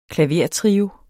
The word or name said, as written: klavertrio